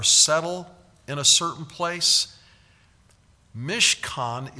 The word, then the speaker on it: mishkan: M-I-S-H-K-A-N is the way you can spell it. Mishkan, that's how you transliterate it. M-I-S-H-K-A-N, mishkan. The word for tent is ohel. O-H-E-L is the way we would transliterate it.